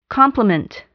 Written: complement

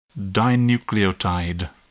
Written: dinucleotide

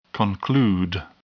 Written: conclude

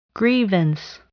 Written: grievance

